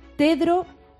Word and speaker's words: Tedros: Pues en vez de Pedro, Tedro. Y en lugar de decirlo bien, pues se dice Ghebreyesus.